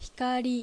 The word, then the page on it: Hikari: Japanese